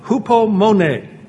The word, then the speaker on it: hupomone: And the Greek word that is used for perseverance or patience is called hupomone. It's spelled H-U-P-O-M-O-N-E, hupomone.